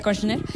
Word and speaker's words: Kushner: Pero resulta ser que el hermano de su esposa, Ivanka Trump, el señor Jared Kushner, así se pronuncia, Kushner,